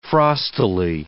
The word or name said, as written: frostily